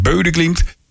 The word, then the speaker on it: Bodø/Glimt: Zo moet je dat uitspreken, Bodø/Glimt.